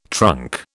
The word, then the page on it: trunk: British English